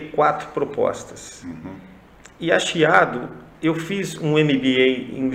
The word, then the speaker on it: Chiado: Aliás, a, a, editora, e agora eu fiquei com medo de pronunciar, porque se fosse um vinho, como que antes seria Quiado, mas é Chiado, Chiado. é Chiado mesmo.